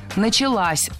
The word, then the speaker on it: началась: Опять же, возвратная часть «с» притягивает ударение к себе. Началась. Ударяем последний слог.